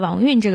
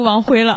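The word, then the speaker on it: 王恽: 所以就是从武先这儿串起来了刚才说的萧豹真和后来接下来我们要说的王运这个人。哎呀，运这个字真的是我最近看电视剧看代孕我才知道怎么读的。你要再往前我肯定读王辉了。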